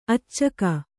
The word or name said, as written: ಅಚ್ಚುಗ